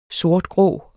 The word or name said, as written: sortgrå